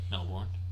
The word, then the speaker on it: Melbourne: So we will specifically be talking about, it's pronounced Melbourne, but I just feel like I'm just going to have to pronounce the R so I don't sound weird.